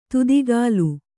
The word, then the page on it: ತುದಿಕಾಲು: tudikālu